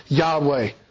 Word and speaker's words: Yahweh: This is the covenantal name of God revealed through the four Hebrew letters which we in English use YHWH, pronounced Yahweh.